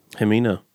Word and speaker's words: Hemina: Hemina. – Okay. – Yeah, Hemina. That's how I would pronounce I would pronounce it. – His thing on stage is, it doesn't rhyme with vagina.